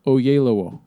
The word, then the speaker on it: Oyelowo: Actually, that name is pronounced how it's spelled. Oyelowo.